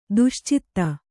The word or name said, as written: ದುಶ್ಚೇತ